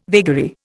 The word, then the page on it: vagary: Indian